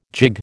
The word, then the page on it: gigue: American English